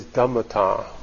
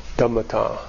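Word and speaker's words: dhammatā: This phrase, nature of things, the Pali word is dhammata. Dhammata. Dhammat, long A, dhammata.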